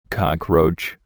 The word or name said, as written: cockroach